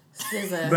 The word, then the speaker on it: SZA: S-Z-A. – SZA.